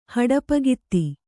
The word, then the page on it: ಹಡಪಗಿತ್ತಿ: Kannada